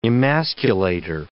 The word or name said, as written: emasculator